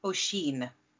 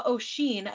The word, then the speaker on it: Oisín: This is a story of Niamh and Oisín. Not pronounced, not written to be pronounced like that at all. I had to Google that.